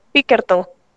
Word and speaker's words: Pinkerton: me parece que se pronuncia de esa manera, Pinkerton o Pinkerton, no recuerdo el apellido, pero es Alan con doble L. Y entonces, Pinkerton. – Es correcto.